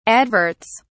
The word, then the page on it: adverts: British English